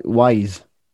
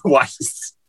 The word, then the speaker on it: Weiss: Because I've been pronouncing it incorrectly. My entire life. Like Wise. – Tim Weiss. – I forgot. Tim Weiss. Yeah. Forgot he was German. Weiss.